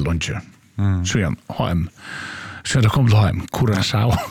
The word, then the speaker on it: Curaçao: Och vi kommer att säga att landarnavnet Curaçao uttalas Cura, K-U-R-A-S-A-O av vårskan Curaçao.